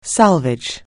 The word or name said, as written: salvage